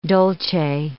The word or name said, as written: dolce